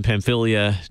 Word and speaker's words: Pamphylia: So no Cilicia and Pamphylia nearby, or no. – Myra or Lycia? – No, you did a very good job pronouncing those, by the way.